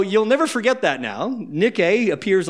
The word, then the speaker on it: Nike: It's the Greek word Nike, but you pronounce it Nike because that's what the athletic shoe company has told you you're supposed to call it. But it's actually Nike.